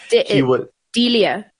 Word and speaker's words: D'Elia: And some of those include, like, you know, controversial guests, the founder of the Proud Boys in the US, the comedian Chris, is it D'Elia? That's how you pronounce his surname. – Chris D'Elia. – Yeah. He is the one who was accused of grooming underage girls and getting like nude images of underage girls.